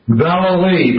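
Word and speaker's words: Vallely: Valley, but if you sing it, Wayne, you'll get it right. Valley.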